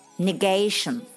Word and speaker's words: negation: 变形词有 negation，注意这个单词的发音和刚才的 negative 不一样。 negation 为什么呢？